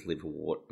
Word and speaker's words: liverwort: Should be pronounced liverwort, but everyone reads it as liverwort because that's how it's spelled.